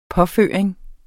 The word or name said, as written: påføring